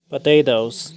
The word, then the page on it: potatoes: /pəˈteɪtoʊz/